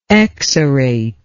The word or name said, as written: exarate